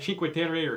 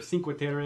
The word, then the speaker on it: Cinque Terre: Cinque Terre or Cinque Terre. – Okay. – People pronounce it different ways.